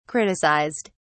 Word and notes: criticized: British English

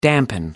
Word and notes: dampen: American English

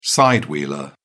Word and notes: side-wheeler: British English

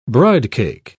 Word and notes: bridecake: British English

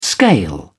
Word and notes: scale: British English

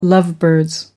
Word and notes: lovebirds: American English

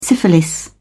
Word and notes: syphilis: British English

